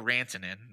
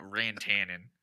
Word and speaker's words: Rantanen: His name is Mikko Rantanen, not Rantanen. That's amazing, though. That's amazing. That was the most Midwestern way you could have pronounced his Finnish last name is Rantanen.